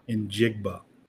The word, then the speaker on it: Njigba: Njigba. Yes. – Okay. – So you say the letter N, and then you just say Jigba.